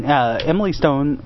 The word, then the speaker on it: Emily Stone: I mean, Emily Stone... Crap, am I getting her name wrong? – No, that's right. – Is that right? – Yeah, you got it.